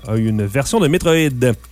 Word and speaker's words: Metroid: Faut le prononcer comme en français. Metroid.